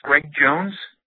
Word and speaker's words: Greg Jones: My name is Greg Jones.